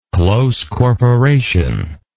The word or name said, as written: close corporation